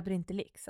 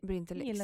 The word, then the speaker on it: Brintellix: Jag gillar Brintellix, att du säger det. – Brintellix, ja. – Det. Den betoningen. – Det låter lite roligare. – Ja, vi kör på den. – Som typ Asterix och Obelix.